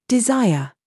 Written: ZOOS

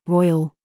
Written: CHOICE

